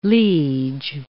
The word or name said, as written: liege